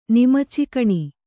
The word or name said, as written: ನಿಮಚಿಕಣಿ